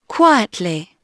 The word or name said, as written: quietly